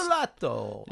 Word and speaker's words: Pizzolatto: The guy's name is Pizzolatto. – Pizzolatto, is that how it's pronounced? – Pizzolatto.